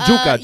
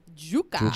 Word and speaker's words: yuca: Or you could say it like Andrew. Juka. – Juka. Like the Yankees. Right. The New York Yankees.